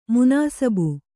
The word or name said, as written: ಮನ್ಸಬ್